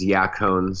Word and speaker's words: yacon: And the best, I think the best syrup or the best sweetener that you can use is yacone syrup. And I'll spell that Y-A-C-O-N.